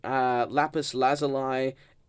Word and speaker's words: lapis lazuli: I believe the lapis lazuli is not consumed when you right-click on redstone. – I've got a question. What is lapis lazuli, or however you pronounce it? – Lapis lazuli.